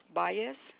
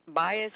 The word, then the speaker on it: Baez: Hermenegilda Baez. – I'm sorry, what was that? – You want me to spell it for you? Baez is my last name.